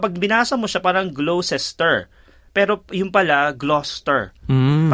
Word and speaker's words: Gloucester: Ito din yung pag binasa mo Gloucester, yung pala Gloucester.